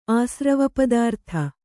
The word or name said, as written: ಆಸ್ರವಪದಾರ್ಥ